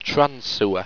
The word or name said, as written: transsewa